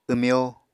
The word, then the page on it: wmyó: Apatani